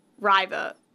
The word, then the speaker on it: Rivah: But it's spelled R-I-V-A-H. Riva. I want to call it River, but with like an U sound. So like Riva, like a British people would say.